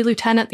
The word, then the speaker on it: lieutenant: Technically, it's a French word, so it would be lieutenant. The Americans say lieutenant.